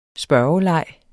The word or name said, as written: spørgeleg